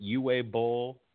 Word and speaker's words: Uwe Boll: Is it Uwe Boll? – Yes.